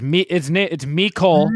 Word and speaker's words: Mecole: You have to say it like that too. – Yeah, it's Mecole.